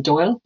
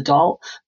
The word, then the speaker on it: Dáil: and creation of a new Irish Parliament called the Dáil, or the Dáil, both pronunciations are used.